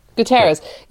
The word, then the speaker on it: Guterres: Is it Guterres? – Yes. – Guterres.